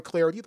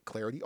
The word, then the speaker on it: Clardy: Hit me up on Twitter at Troy Clarity. The last name is spelled C-L-A-R-D-Y, at Troy Clarity.